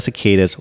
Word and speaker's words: cicada: so the sound of the cicadas was mostly the only thing that one could hear in the city during that time.